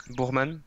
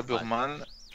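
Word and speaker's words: Buchmann: En 2, j'arrive jamais à le prononcer, Burkman. – Bourman. – Ouais, Burman.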